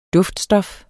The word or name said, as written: DUFTSTOFFET